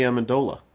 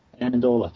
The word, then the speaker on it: Amendola: Oh, Danny Amendola. – Amendola, thank you.